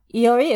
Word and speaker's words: Iori: Don't forget, when you're looking me up, Iori is spelled I-O-R-I. There are no L's in my name.